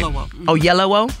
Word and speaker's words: Oyelowo: Well, on Friday, I interviewed for another movie, Lakeith Stanfield and David Oyelowo. I believe I said that right. – Oyelowo.